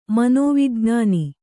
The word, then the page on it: ಮನೋವಿಜ್ಞಾನಿ: manōvijñāni